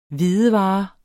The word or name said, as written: HVIDEVARERNES